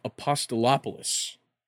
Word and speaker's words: Apostolopoulos: As well as the guy we talked about either last week or the week before, Canadian billionaire Steve Apostolopoulos. I heard them say it on ESPN, so I'm confident in it now.